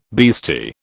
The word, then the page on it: beastie: British English